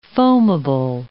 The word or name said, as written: foamable